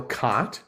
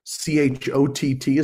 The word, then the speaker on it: Chott: I would love it if we can get some more followers to follow me over there on TikTok at Bill Cott. Chott is the way you spell that last name, and maybe you can put that link on your website.